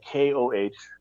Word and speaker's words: Kohout: My last name is spelled Kohout, and I just use my shortened first name, Ed.